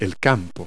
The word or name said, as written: field